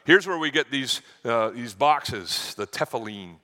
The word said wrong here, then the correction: tefillin: Or tefillin, I'm sorry, I said it wrong, of the Hebrew faith.